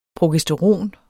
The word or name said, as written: progesteron